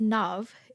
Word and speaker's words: Nav: My name is Nav. It rhymes with dove or love. But yeah, it's not actually Nav.